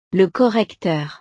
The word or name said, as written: le correcteur